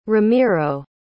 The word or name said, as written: ramiro